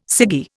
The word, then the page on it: ciggy: Indian